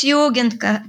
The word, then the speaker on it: Jugend: How, how do you say that? – It's Jugend Cafe.